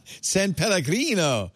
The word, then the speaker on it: San Pellegrino: Spanger della Grande. – San Pellegrino.